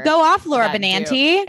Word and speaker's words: Benanti: I always feel like I say Laura Benanti's name wrong. I think it's Benanti. Anyways,